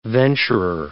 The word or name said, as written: venturer